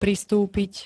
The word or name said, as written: pristúpiť